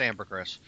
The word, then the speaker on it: Ambergris: It's pronounced Ambergris. – Ambergris, okay.